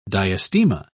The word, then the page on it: diastema: British English